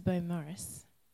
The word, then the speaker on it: Beaumaris: Sorry, I pronounced it wrong before. I drove here to Beaumaris.